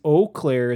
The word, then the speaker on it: Eau Claire: It is Eau Claire is the correct French pronunciation. Eau Claire is what the like people in Michigan say because we can't pronounce crap even though we're settled by the French.